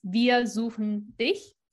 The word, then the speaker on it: wir suchen dich: And if you stick to English pronunciations of like how you pronounce CH and all of that. – Right. Right. – That is definitely how you would read that. But again, in German, it's wir suchen dich, right?